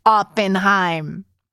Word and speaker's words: Oppenheim: Like my 6-year-olds know Jason and Brett's last name is pronounced Oppenheim. So, yes, Janelle from Winnipeg.